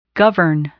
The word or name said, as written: govern